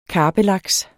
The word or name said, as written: karpelaks